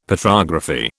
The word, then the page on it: Petrography: British English